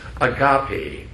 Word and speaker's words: agape: The fourth word is agape, or agape, I should say. A-G-A-P-E. Agape. Agape. I always get the wrong syllable.